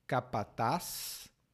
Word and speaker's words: capataz: Essa palavra tem duas pronúncias. Você pode dizer capataz ou capataz. Os dois são corretos.